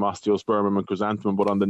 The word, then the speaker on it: osteospermum: An osteopernium? – Nearly, osteospermum.